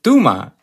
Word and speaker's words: Touma: Well, that sounds like the perfect pronunciation in Spanish, but I'll let you know, my last name is from Palestine, so it's actually pronounced Touma.